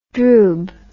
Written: droob

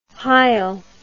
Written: heil

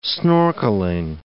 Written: snorkeling

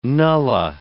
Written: nullah

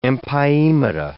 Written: empyemata

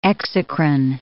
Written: exocrine

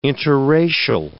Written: interracial

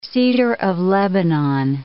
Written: cedar of Lebanon

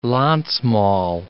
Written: Landsmal

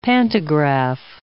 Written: pantograph

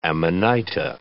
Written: amanita

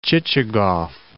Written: Chichagof

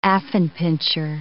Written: affenpinscher

